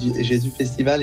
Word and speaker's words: Jesus: Et puis on a assez vite convergé sur le nom de Jésus, alors qu'on prononce souvent à l'anglaise. On prononce Jesus Festival, mais peu importe, il y en a qui disent Jésus Festival et ça fonctionne bien.